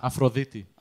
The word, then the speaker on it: Aphrodite: How do you say it, though? – Aphrodite.